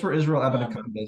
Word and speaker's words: Abanikanda: Ab Ab Abiniconda is how you say Ab- Abanikanda.